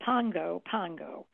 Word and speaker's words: Pago Pago: And its capital city is Pago Pago, even though it's spelled P-A-G-O, it's pronounced what? – Pago Pago.